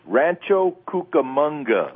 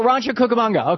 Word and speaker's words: Rancho Cucamonga: I can't pronounce that city in California. What is it? – Rancho Cucamonga. – Oh, Rancho Cucamonga.